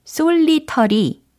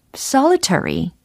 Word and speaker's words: solitary: 발음이 과연 어떻게 될까요? 일단 철자는 접수되셨고, 발음을 한번 해보세요. 자신있게? 아, 솔리터리 아니면 솔리테리. 요거 다 아니겠죠? 예, 정확하게 최대한 연습을 해봐야 되겠습니다. solitary, solitary, solitary.